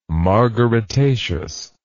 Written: margaritaceous

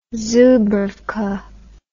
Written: zubrowka

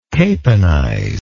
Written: caponize

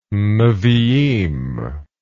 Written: neviim

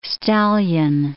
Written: stallion

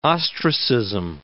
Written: ostracism

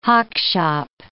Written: hockshop

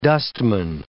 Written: dustman

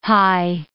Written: hie

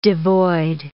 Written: devoid

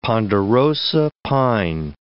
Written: ponderosa pine

